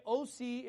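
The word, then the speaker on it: Osee: Romans chapter 9, verse 25 says, "As he saith also in OC," by the way, OC is just the New Testament pronunciation of Hosea, okay? So Hosea in the Old Testament is pronounced in the New Testament in Greek, OC, okay?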